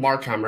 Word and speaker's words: Markham: So when you're from Markham, right? – Markham.